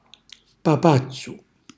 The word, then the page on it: papazzu: calabrese